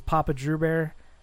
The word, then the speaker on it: Papa Drew Bear: My username is Papa Drew Bear. It sounds like it's spelled like it sounds.